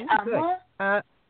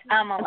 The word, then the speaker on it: Amla: It's Amala. – Good. Pamela? – It's Amala. – I'm sorry? – It's Amala. – Could you spell that, love? – It's Amala? – A-M-L-A.